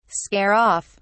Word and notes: scare off: British English

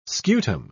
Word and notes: scutum: British English

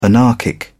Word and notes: anarchic: British English

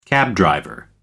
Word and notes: cabdriver: British English

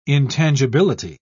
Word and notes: intangibility: British English

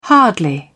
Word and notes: hardly: British English

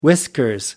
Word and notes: whiskers: British English

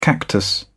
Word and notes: cactus: British English

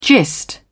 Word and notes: gist: British English

